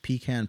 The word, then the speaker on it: pecan: We have s'mores coming up, pretzel and brown sugar, pecan pie, which a whole pie is folded into that. – I appreciate the fact you called it pecan. – Pecan. Is it pronounced any other way? – Yeah, go ahead.